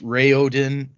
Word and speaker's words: Raoden: So I'm going to say Raoden because that's how I say it in my head, and Hrathen, even though technically it's Raoden and Hrathen,